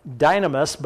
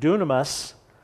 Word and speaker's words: dunamis: This looks like dynamis, but it's actually pronounced in the Greek dunamis.